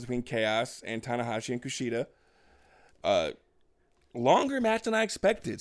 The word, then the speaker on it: Kushida: And Kushida, which I also nailed.